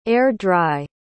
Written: air-dry

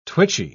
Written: twitchy

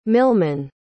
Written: milman

